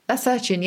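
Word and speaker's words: lacertine: Lacertian, yeah.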